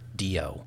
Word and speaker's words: Gisondo: Skyler Gisondo? – Sure. – Gisondo.